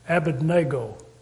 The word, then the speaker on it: Abednego: Now, Daniel and his three young royal friends, Shadrach, Meshach, and somebody says it's pronounced Abednego. We always called it Shadrach, Meshach, and Abednego.